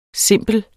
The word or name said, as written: simplere